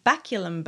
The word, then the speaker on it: baculum: On baculum bones. – Okay. – So I'm pretty sure that's how you pronounce it. It's B-A-C-U-L-U-M. – Yep. – Baculum. – That's how I would pronounce it.